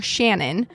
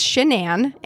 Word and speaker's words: Shanann: And a lot of people get her name wrong, trying to call her Shannon, but it's Shanann.